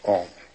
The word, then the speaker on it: AUM: It's written A-U-M. You start in the back of the mouth, ah. And then ooh, you fill the mouth. And mm closes at the mouth. And when you pronounce this properly, all vowel sounds are in that pronunciation.